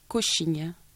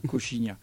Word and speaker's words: Coxinha: Alors ça s'écrit, moi je l'ai... ça s'écrit, alors c'est C-O-X-I-N-H-A, Cochine. – Cochine. Ah, Cochine.